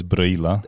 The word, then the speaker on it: Brăila: That's Brăila, Brăila, right? Yeah. – Pronounced in Romanian.